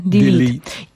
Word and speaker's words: delete: Delayed? – Tak błędnie. Delayed? Się wymawia, tak? Niby? – Yeah, I don't know. – Oczywiście to jest błędne, bo mówi się tylko i wyłącznie delete.